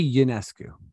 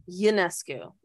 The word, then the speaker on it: Ionescu: And I always get, is it Ionescu or is it Ionescu? Like, do you overpronounce the I? – I say Ionescu. – Ionescu. Yeah, that's right.